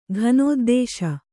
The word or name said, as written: ಘನೋದ್ದೇಶ